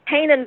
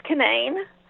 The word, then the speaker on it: Canaan: Everybody calls it Canaan Valley because in the Bible it's pronounced Canaan.